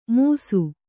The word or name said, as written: ಮುಸು